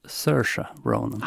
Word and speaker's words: Saoirse Ronan: Hon säger ju själv att det uttalas Saoirse Ronan.